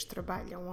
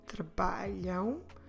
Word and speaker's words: trabalham: Make sure when you do trabalham, trabalham ends with a M, A-M, because it's the plural. But we don't stress the A-M. We're not stressing the last syllable. We're stressing the one before, the penultimate, the second to last one. So make sure you say trabalham. And make sure you close your lips when you finish pronouncing the verb.